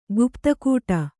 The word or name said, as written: ಗುಪ್ತಕೂಟ